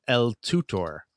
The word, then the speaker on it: L2TOR: L2TOR should be pronounced El Tutor.